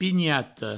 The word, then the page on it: pinhata: pignato